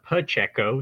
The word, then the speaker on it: Pacheco: It is a Pacheco, so you kind of pronounce it like a P-U instead of a P-A.